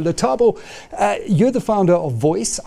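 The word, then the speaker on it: VOYC: Yes, it's pronounced VOICE, standing for voice of your customer.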